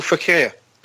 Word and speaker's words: Fekir: You know, everything goes through Nabil Fekir. That's how you pronounce his name, Matt.